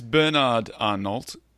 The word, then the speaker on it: Bernard Arnault: So it's Bernard Arnault. How do you say his name? – I think you're right.